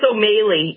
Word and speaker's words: O'Malley: Yeah, everybody wants to say O'Malley. It's O'Malley. It actually is the same as O'Malley, just something about the original Gaelic spelling, but my name is pronounced with the long A.